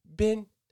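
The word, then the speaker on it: bing (冰): It's like, it's pronounced bing. Wait, how did he say?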